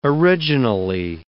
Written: originally

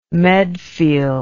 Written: medfield

